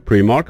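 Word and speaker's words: Primark: من توی یوتیوب هم سرچ میکردم، میرفتن مردم میپرسن پرایمارک هست یا پریمارک، حالا ما صداش میکنیم پرایمارک.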